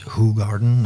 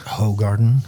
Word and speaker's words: Hoegaarden: And yes, it's Hoegaarden, not Hoegaarden.